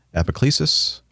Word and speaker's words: epiclesis: Epiclesis, oblation, anamnesis, or anaphora? Am I pronouncing those correctly? – Yep. You actually did pretty well.